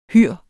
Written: hyr

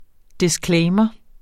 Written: disclaimer

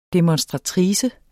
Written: demonstratrice

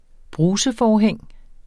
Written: bruseforhæng

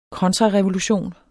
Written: kontrarevolution

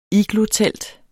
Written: iglotelt

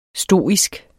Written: STOISK

